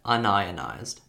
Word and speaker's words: unionized: And you can see that once you separate the word into union and ized, so U N I O N and I Z E D, that's one way to pronounce it. But you can actually separate it into un, so U N, and I O N I Z E D. And that would be pronounced un-ionized.